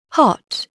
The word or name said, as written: hot